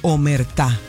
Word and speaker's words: Omertá: Hoy, a esta hora quiero hablarles de omertá. ¿Qué es omertá? – Omertá. – Omertá. Es omertá, porque lleva tilde en la A.